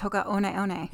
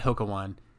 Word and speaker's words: Hoka One One: It's Hoka One One. – Yeah. People call it Hoka One, but it's actually Hoka One. Hoka One One.